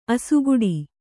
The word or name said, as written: ಅಸುಗೆಡು